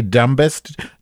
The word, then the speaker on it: dumbest: So what I'm Excuse me, did you say dumbest? The, the B is silent, you asshole.